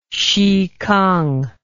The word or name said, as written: xikang